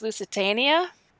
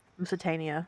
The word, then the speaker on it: Lusitania: Okay, so Susan says it's a long A. So HMS Lusitania? HMS Lusitania. Yeah, that sounds familiar to me too. Lusitania.